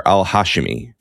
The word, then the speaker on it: Al-Hashimi: But I also noticed that no one is pronouncing her name correctly, even when they use her full name. Everyone is saying Dr. Al-Hashimi, and I didn't even pronounce it right before. And I think the way she pronounced it was Dr. Al-Hashimi. – Okay. – Which is different, right? And you know, Yeah. The emphasis of the syllable.